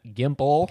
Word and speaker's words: Gimple: written by Scott M. Gimple. – God, you sound a little special. – Rhymes with pimple.